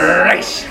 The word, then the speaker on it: Reish: Reish. That's how you say it.